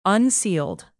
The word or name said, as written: unsealed